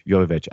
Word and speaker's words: Jovovich: Jovovich.